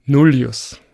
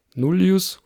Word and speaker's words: nullius: Und tatsächlich ist bei Nullius oder Nullius oder Nullius das I im Gegensatz zum zweiten I von Filius lang.